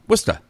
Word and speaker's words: Worcester: I mean, there's a town in New England. It's W-O-R-C-H-E-S-T-R. Worcester.